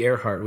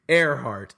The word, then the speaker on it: Ehrhardt: At B Earhart, which is B-E-H-R-H-A-R-D-T. – Can you spell that one more time? B-E-A-R. – I can't. I got to buy a vowel. I don't know. It's B for Bobby Earhart. E-H-R-H-A-R-D-T.